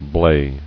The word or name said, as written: Blae